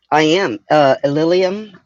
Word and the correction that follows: Illenium: I know I'm saying his name wrong. – Illenium.